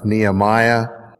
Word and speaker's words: Nehemiah: Our first lesson is from the 8th chapter of Nehemiah. I even pronounced Nehemiah right.